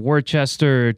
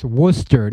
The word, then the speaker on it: Worcester: Number two, it's pronounced Worcester, not Worcester. – There Worcester is a sauce you put on steak. Worcester. – Worcester. – If you want to be a Bostonian, it's Worcester.